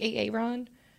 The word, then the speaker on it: Erin: I'm like, mine's spelled E-R-I-N. – And they call you Ron? – Yeah. Oh. Well, just anytime you say your name's Aaron, a lot of people are like, A-A-Ron. And I'm like, no, no. E-R-I-N.